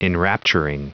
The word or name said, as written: enrapturing